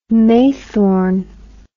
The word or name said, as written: maythorn